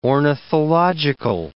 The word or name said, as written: ornithological